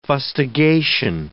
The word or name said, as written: fustigation